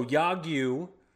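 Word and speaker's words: Yagyu (야구): So anyway, so Yagyu, that's how you pronounce the word for baseball in Korean, South Korean.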